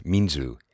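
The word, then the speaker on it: 民主: minshu, pronounced minzu in Chinese and literally meaning people as primary.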